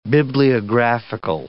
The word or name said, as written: bibliographical